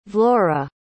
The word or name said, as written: vlora